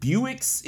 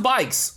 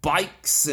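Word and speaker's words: Buycks: Is it Buicks? How do you pronounce his name? It's pronounced Bikes. Okay, Dwight Bikes.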